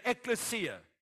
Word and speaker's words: ecclesia: The ecclesia.